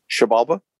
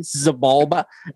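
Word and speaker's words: Xibalba: How do you say it? – Shebalba. – Shebalba. I've always said it the white boy way. Zebalba. Exabalba. – Yeah. – I've always called it Zebalba, but I've been friends with you guys for so long that I figure I need to start saying it right.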